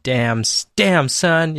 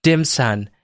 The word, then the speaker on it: Damson: So in a nutshell, I started a record label called Damson. You have to say it like that. Yeah, otherwise, it sounds like Dimson.